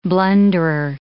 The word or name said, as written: blunderer